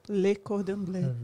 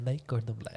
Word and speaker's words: Le Cordon Bleu: E não é Cordon Bleu, como é que fala? Le Cordon Bleu. Le Cordon Bleu, é isso aí.